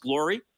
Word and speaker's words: Gloire: His name is spelled G-L-O-I-R-E, if people see it, but it's pronounced Glory? – Correct, yeah.